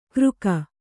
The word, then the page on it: ಕೃಕ: Kannada